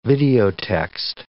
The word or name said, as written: videotext